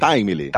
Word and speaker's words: Timely: O Time Link. Eu tô pronunciando correto? É Time Link? É, Timely, Timely. – Timely. – Timely, é. De Timely Comics, que era o nome original da Marvel e tal, tem esse lance.